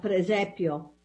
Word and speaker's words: presepio: The second thing that you notice is that, you know, they have the uh, uh, presepe, is how you say the, na- the nativity scenes? No, presepio.